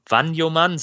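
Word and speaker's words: Vagnoman: Äh, Joscha Wa Wanjoman, so jetzt habe ich es. Wanjoman habe ich gelernt äh, von einem HSV-Fan, so wird er ausgesprochen, trainiert wieder, nachdem er dieses Ödem am Mittelfuß hatte.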